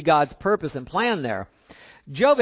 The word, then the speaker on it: Job: We're reminded of Job here as a picture of the purpose of the Lord. Things didn't look good at all. If you don't know Job, that's how that's pronounced. It's not Job.